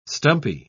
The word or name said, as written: stumpy